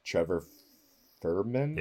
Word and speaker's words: Trevor Fehrman: But you totally know Brian O'Halloran, Jeff Anderson, Trevor Fehrman, yeah.